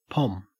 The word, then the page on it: pom: Australian English